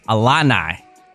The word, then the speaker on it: Illini: Illini.